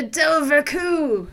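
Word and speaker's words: dobhar-chú: Is that how you say it? – It is. Now, I wouldn't have known that if I didn't watch this documentary with this amazing little Irish cryptozoologist, because it's actually spelled D-O-B-H-A-R-C-H-U, but not just a regular U.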